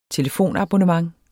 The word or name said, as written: telefonabonnent